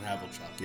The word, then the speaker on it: Havlicek: It is John Havlicek. – John Havlicek.